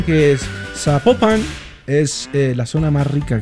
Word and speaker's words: Zapopan: Nos escriben los oyentes y que no se, no se pronuncia Zapopan, sino que se pronuncia Zapopan. – Zapopan.